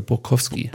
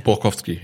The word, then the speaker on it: Borkowski: Haben sie ja Con äh, äh, Conte und den anderen, den ich jetzt äh, schon mehrfach falsch ausgesprochen habe. Bo Borkowski, meine ich, heißt er. Borkowski. Borkowski, ja. Ja, Borkowski ähm, von, von Leipzig ausgeliehen.